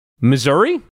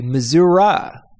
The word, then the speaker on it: Missouri: How do you pronounce the state that Kansas City, bigger Kansas City, and St. Louis are located in? Missouri? People in that state say Missouri. Missouri. Oh, Missouri.